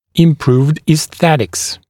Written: improved aesthetics

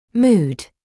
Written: mood

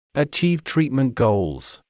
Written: achieve treatment goals

